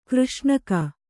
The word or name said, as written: ಕೃಷ್ಣಿಕೆ